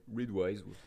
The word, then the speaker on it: Readwise: je l'ai bien prononcé Readwise, donc R-E-A-D. E en étant sage. – R-E-A-D-W-I-S-E.